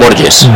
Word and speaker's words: Borges: La pelota tocando Borges, ¿cómo se dice? ¿Borges o Borges? – Borges, yo creo, Borges, ¿verdad? Sí, porque sí. Los portugueses lo pronuncian así, vaya.